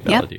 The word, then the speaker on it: Validere: Okay, Valadier.